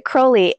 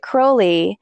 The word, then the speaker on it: Crowley: I pronounce it I pronounce it Crowley, and I learned a rhyme a while ago that they said, "You be if you pronounce it Crowley, if you believed he behaved holy, and you Right. – pronounce it Crowley, if you believe that he behaved foully."